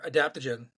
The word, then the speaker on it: adaptogen: So you threw out a word there, adaptogen.